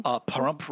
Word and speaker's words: Pahrump: How do you pronounce her book? The Pahrump? – Pahrump Report.